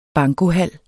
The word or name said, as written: bankohal